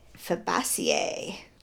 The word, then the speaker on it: Fabaceae: Fabaceae. – That is barely a word. – I did a good job trying to pronounce it. – Fabaceae. It sounds like fava beans.